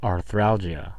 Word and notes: arthralgia: American English

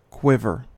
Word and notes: quiver: American English

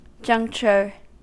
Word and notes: juncture: American English

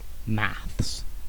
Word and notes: maths: British English

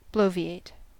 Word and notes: bloviate: American English

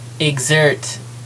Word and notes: exert: American English

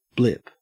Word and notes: blip: Australian English

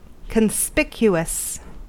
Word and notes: conspicuous: American English

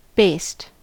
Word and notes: based: American English

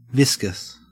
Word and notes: viscous: Australian English

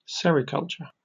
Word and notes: sericulture: British English

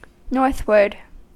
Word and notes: northward: American English

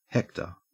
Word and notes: hector: Australian English

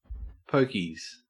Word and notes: pokies: Australian English

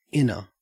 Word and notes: inner: Australian English

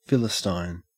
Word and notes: philistine: Australian English

